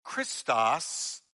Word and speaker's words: Christos: Christ is pronounced Christos in the Greek language, and it means anointed or anointed one.